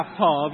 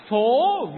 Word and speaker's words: phố: À, có bạn đọc là phở Việt Nam, nhưng mà ý tác giả ở đây đó là phố Việt Nam, đường phố Việt Nam.